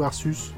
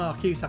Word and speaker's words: Arceus: Euh, ça se prononce euh, Arceus ou Arsus Non, non, Arceus, Arceus.